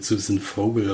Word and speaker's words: Susan: Nee, die wird garantiert Susan Vogel ausgesprochen. Vogel auch nicht mit V, sondern mit F.